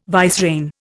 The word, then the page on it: vicereine: Indian